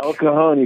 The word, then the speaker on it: El Cajon: Let me get in El Cajon. Is that how you pronounce it? California. – El Cajon, you got it right.